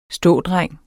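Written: stådreng

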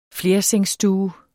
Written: flersengsstue

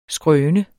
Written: skrøne

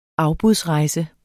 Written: afbudsrejse

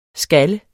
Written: skal

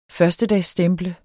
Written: førstedagsstemple

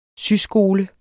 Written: syskole

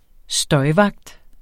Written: støjvagt

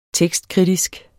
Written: tekstkritisk

